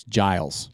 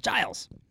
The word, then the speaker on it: Giles: So it's Georgia G. But rhymes with Miles. – Yeah. – So it's Giles. – Giles.